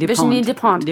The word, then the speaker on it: Virginie Despentes: wo wir wieder bei deiner großen Vorbildfrau Virginie Despentes, muss das aussprechen. – Virginie Despentes.